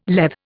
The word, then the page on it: Lethe: Indian